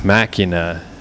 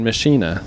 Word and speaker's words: Machina: I would have called them Adore and Machina, but apparently it's Adore and Machina, so now you know.